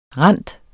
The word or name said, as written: rinde